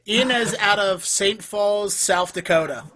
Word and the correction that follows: Sioux Falls: Sioux Falls. – Sioux Falls, South Dakota, which is spelled S-O-U-I-X. S-I-O-U-X.